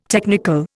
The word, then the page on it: technical: Indian